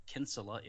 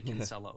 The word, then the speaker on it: Kinsella: And you may be able to tell from my surname that it's Irish, so it's pronounced Kinsella in Ireland. – Oh. – But over here, yeah, we do say Kinsella over here.